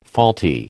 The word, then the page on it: faulty: American English